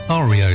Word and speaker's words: REO: And uh, it, REO Speedwagon are named after a truck in America called a Rio Speedwagon. But they changed the pronunciation to REO Speedwagon.